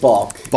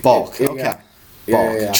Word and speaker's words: Baalke: It's Baalke. – Baalke.